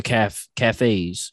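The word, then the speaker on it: café: Anytime there's a Starbucks coming up in the neighborhood, she's like, Oh, here they go with the cafes. She calls them calves because she doesn't realize that it's pronounced cafe, so she just calls them calves.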